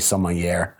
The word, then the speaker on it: sommelier: Or do we just not know enough words, like a sommelier? How do you say it again? – Sommelier.